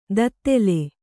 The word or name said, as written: ದತ್ತೆಲೆ